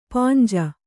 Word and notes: ಪಾಂಜ: Kannada

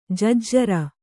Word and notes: ಜಜ್ಜರ: jajjara

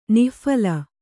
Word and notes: ನಿಃಫಲ: nih phala

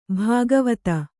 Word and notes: ಭಾಗವತ: bhāgavata

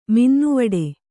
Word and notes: ಮಿನ್ನುವಡೆ: Kannada

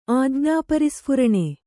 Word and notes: ಆಜ್ಞಾಪರಿಸ್ಫುರಣೆ: Kannada

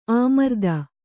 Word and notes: ಆಮರ್ದ: āmarda

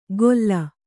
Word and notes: ಗೊಲ್ಲ: Kannada

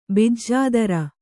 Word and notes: ಬಿಜ್ಜೋದರ: bijjōdara

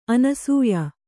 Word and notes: ಅನಸೂಯೆ: anasūye

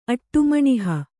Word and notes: ಅಟ್ಟುಮಣಿಹ: kannada

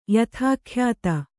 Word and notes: ಯಥಾಖ್ಯಾತ: Kannada

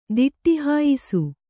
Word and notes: ದಿಟ್ಟಿಹಾಯಿಸು: diṭṭI hāyisu

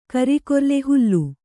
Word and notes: ಹೋಲು: Kannada

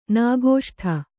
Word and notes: ನಾಗೋಷ್ಠ: kannada

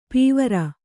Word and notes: ಪೀವರ: pīvara

